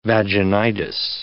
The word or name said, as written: vaginitis